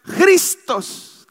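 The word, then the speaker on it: Christos (Χριστός): Y se pronuncia Christos, que quiere decir el ungido.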